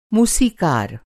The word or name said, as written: मूसीक़ार